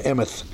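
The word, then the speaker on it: emeth (אמת): Several words for truth, emeth is the usual word for truth in the Old Testament, not emet, but it's spelled like that, E-M-I-T, but it's a long T-H sound, emeth, and it means truth.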